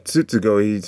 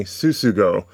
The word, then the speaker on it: Tsutsugo: I think the people I heard one thing on Facebook, on YouTube, and the guy is not saying Tsutsugo. He's pronouncing the word as if the American style of saying tsunami, mm-hmm. by making the T silent. So he's saying Tsutsugo, and I'm like, this is a problem.